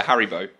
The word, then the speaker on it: Haribo: Haribo in England. – Haribo? – Haribo. Yeah. – Haribo? – Like Harry. And then how you tie your shoelaces.